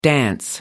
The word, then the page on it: dance: American English